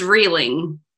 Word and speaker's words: Rieling: It's Rieling.